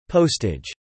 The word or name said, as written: postage